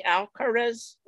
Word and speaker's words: Alcaraz: In tennis, if you didn't follow over the weekend, there's this kid, Carlos Alcaraz. Did I pronounce that correctly, Danny? Alcaraz? – Yeah.